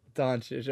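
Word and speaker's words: Dončić: Doncic, okay.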